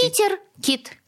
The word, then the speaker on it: ПитерКИТ: Скажите мне, как правильно произносить? Питер Кит. Питер Кит.